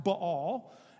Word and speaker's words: Baal: We often say Baal. It's actually pronounced Baal.